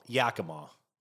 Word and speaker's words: Yakima: Yakima.